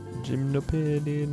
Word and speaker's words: Gymnopédie: Gymnopédie number one.